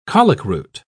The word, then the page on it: colicroot: British English